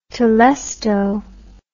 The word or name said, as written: telesto